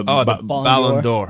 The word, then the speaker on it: Ballon d'Or: Ballon d'Or.